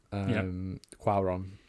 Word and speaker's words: Cuarón: Oh yeah, it's a 2006 film by directed by Alfonso Cuarón. Cuarón. I've looked up the pronunciation, and it cost about $77 million to make and made $70 million.